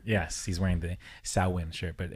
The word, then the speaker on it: Samhain: I heard there's a Celtic way to say that, you know, or Gaelic way, the proper way to say Samhain, you know? – Oh, Samhain.